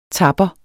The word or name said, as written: tapper